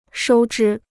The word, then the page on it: 收支: shōu zhī